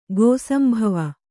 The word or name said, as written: ಗೋಸಂಭವ